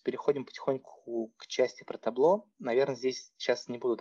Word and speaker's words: Tableau: Так как она пишется на французский манер таблеау. то его часто очень читают каким-то там странным образом. А, оно на именно американский манер, ну, ну читается как просто табло.